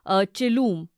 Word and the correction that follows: Chilume: I hope I'm pronouncing the name correctly. – It's pronounced Chilume.